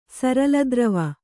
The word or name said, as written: ಸರಲದ್ರವ